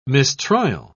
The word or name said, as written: mistrial